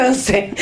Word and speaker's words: Once: Se escribe Once, como en inglés Once. – Como Once Upon a Time.